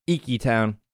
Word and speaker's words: Iki Town: We start in Iki Town? I think it's like Tiki with no T, right? – I think so. – Iki Town.